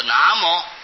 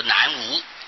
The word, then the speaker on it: 南无: 那么本来这个无啊就是客家话的摩，啊、所以要念作南摩，应当是以客家话来念最正确了。那么。现在一般是按照这个字在念呢，就念成南方没有啊，闹出笑话。